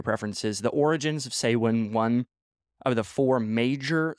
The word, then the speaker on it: Samhain: So started out calling, it's called Samhain. So it's spelled S-A-M-H-A-I-N. So yeah, I had to Google that. I did not know that before, but it's called Samhain. So S-A-H-W-N. So like Samhain or Samhain, kind of depends on your preference, I guess.